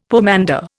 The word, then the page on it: pomander: Indian